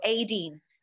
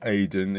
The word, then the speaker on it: Aideen: Her name is Aideen McQueen. You know, the proper style. Not just the short Aiden. It's like Aideen. You've got to remember that. I'll remember that in the future.